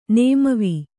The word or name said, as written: ನೇಮವೀ